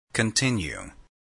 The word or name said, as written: continue